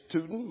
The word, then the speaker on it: Tooten: The last name is Tooten, my surname, which came from my family.